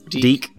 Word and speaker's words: DIC: Also, so at the time, the animation company they were working with to make it happen, which is DIC, I don't know whether it's pronounced Dick or whatever, but they were Deek.